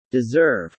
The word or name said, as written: deserve